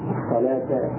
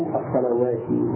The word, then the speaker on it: الصلاة: فنقول الصلاة والزكاة والحياة ولا نقول كما هي مرسومة الصلوسة والزكوسة والحيوسة أو الصلوات والزكوات والحيوات،